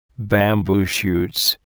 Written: bamboo shoots

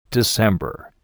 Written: December

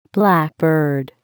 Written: blackbird

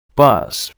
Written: bus

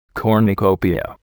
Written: cornucopia

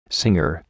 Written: singer

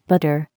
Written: butter

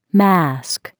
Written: mask

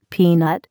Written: peanut

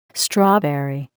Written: strawberry

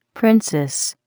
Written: princess

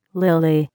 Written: lily

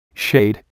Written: shade

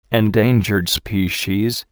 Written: endangered species

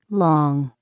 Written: long

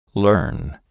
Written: learn

